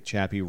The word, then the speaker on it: Chappie: And unfortunately, Chappie rhymes with happy.